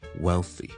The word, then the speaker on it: wealthy: W-E-A-L-T-H-Y, wealthy, wealthy. 点解特别会揀呢个字嚟讲呢个发音原因系的确有少少难嘅。因为 wealth 我哋先有一个 L 音喺度。 well, 个条脷要上去 L, 啦啦啦呢个位。